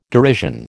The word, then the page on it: derision: American English